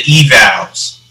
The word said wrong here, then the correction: Evils: Oh, did I say that wrong? Damn, I said the Evals. – I got to figure out a way how to make people, because it is like an evaluation, eval. Right. But it's Evils.